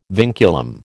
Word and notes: vinculum: American English

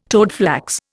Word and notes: toadflax: Indian

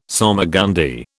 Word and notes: salmagundi: British English

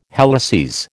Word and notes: helices: American English

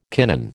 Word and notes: kinin: American English

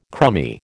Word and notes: crumby: American English

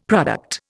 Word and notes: product: Indian

